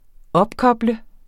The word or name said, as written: opkoble